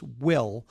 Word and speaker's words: WHILL: So your company name is Will. But yes. It's spelled W-H-I-L-L. So it's Will yes. with an H, W-H-I-L-L, and it's dot U-S. So Will, W-H-I-L-L dot U-S.